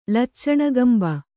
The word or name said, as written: ಲಚ್ಚಣಗಂಬ